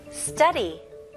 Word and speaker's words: study: 영어 발음은 이음절로 sta, 그래서 stu까지 같이 연결을 하셔야 돼요. study, study, study. Okay? 그래서 강세가 첫 음절에 들어갑니다.